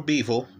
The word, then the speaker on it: Bivol: Bivol, or Bivol, as it's more accurately pronounced, we'll forget the Dwyer pronunciation here, is also in his prime.